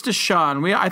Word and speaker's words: DeShone: Or Deshone, is it Deshone Kizer? Deshone Kizer? – I believe it's Deshone. I believe it's Deshone. – Phonetically, it's Deshone, but I'll whatever.